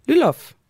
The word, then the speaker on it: Lyloff: Og så har vi fået besøg af studiet af Monika Lyl, Lyloff. Jeg ved ikke, om det er rigtig øh, udtalt, Monika. – Jo, det er helt rigtigt.